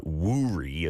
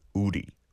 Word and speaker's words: 우리: But when you see it romanized, like there's a bank that's called that, it's W-O-O-R-I, so it looks like wuri as well. But the correct pronunciation in Korean, uri, uri.